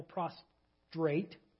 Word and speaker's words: prostrate: When all the people saw this, they fell prostrate. Yes, that's the correct way to say it.